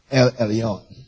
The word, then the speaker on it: El Elyon: Elyon is E-L-Y-O-N. So, it's E-L dash E-L-Y-O-N. O-N. I believe it should be pronounced Elyon. El Elyon.